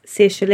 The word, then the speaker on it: Saoirse: So it's Saoirse Linda. So Saoirse is spelt nothing like it sounds.